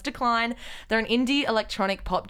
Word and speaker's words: Deklyne: but it's spelled D-E-K-L-E-Y-N, but they've made it very clear across all of their social media channels that it is pronounced Decline. – It looks like Declan. – Yeah, it's like Declan.